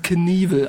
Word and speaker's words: Knievel: Aber ich weiß, er wird Knievel ausgesprochen. Knievel, ja.